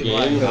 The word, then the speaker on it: Game Boy: Gobo Boy? Como é que é? Gobo Boy, tu conheceu? Game, Game Boy.